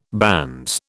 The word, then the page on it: banns: British English